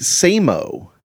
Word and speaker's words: SAMO: It's actually pronounced Samo.